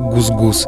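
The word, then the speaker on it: GusGus: В ее произношении это звучит как гус-гус.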